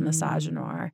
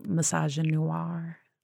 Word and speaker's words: misogynoir: It is the perfect example of just misogynoir. And did I say it right that time? Misogynoir. – Yeah, yeah, yeah. Misogynoir.